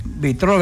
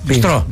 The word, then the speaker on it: μπιστρό: Μπιτρό δεν υπάρχει. Μπιτρό. – Ναι. – Μπιστρό λέγεται. – Μπιστρό. – Ναι. – Εντάξει, μπιστρό. Δεν τονίζετε πολλές φορές το S.